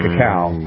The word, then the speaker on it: cacao: Unfortunately, when we're dealing with processed chocolate, it's not the same as when we're dealing with the high content of cacao. I think I'm saying that right. Not homeopathic cow, but cacao, right? – Yeah.